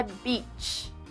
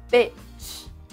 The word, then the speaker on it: beach: Praia em inglês é beach. O xingamento é bitch. Repita comigo, bitch.